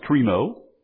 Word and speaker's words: krino: It's the form of the word judgment, which in the Greek would be pronounced krino. That's what's in verse 5, krino.